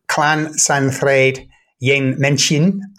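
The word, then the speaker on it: Llansantffraid-ym-Mechain: called Clan Santhraid Yen Menchin. I think that's the Welsh pronunciation.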